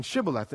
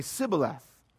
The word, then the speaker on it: Shibboleth: Ephraimites couldn't pronounce the sh in Shibboleth, and so they would say Sibboleth.